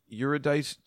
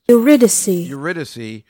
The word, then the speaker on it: Eurydice: Eurydice. Eurydice.